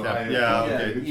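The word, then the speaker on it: Koepp: I'm basing that pronunciation on Steven Spielberg mentioning his name in the documentary about Jurassic Park. – Oh, okay. – He called him David Koep, so Koep.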